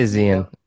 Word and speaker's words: Xian: How do you properly pronounce it? – I say Xian.